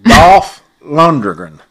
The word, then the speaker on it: Dolph Lundgren: Dolph Lundgren.